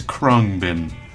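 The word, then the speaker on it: Khruangbin: That's right, the band is Krungbin. I only recently learned how to say it right when I saw them live.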